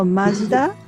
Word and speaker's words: Mazda: Okay, so Mazda in Japan, which is pronounced as Mazda, is located in Hiroshima.